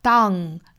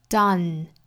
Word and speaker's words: done: 首先核心要找好元音的口型，开口度大了一点或者是小了一点都是不可能对的，还要注意前后的辅音，比如说这个词如果后面的辅音读成了后鼻音的话 down 那就是变成了嗯的发音，实际上应该是前鼻音，舌尖去抵上齿龈发嗯 done.